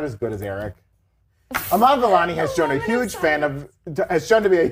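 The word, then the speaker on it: Iman Vellani: Amon Vellani has shown... Am I saying your name right? I'm so bad. Eric says it right. Amon Vellani? – Yeah.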